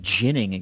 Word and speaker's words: Ginning: Adam how about Adam Ginning getting a call up? Yeah, the old soft J.